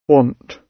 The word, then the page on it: want: British English